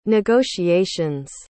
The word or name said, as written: negotiations